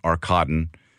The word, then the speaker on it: Arkadin: It's spelled A-R-K-A-D-I-N, pronounced Arcaden.